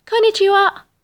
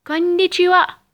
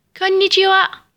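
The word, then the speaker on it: كونيتشيوا (こんにちは): وللأسف فالكثير يخطأ في نطقها فيقول كونيتشيوا وهي في الأساس كونيتشيوا بشد النون لذلك انتبهوا رددوا ورائي كونيتشيوا كونيتشيوا ممتاز فلننتقل للعبارة التالية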